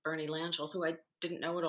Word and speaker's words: Langille: Well, actually, Bernie Langille, you can pronounce it Langille or Langille, but the Langilles themselves go by Langille.